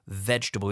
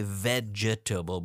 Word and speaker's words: vegetable: Vegetable. We don't say vegetable, but vegetable.